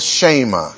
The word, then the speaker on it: Shema: The word for hear is Shammai. Or sometimes it gets pronounced the Shema.